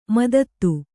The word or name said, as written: ಮದದ್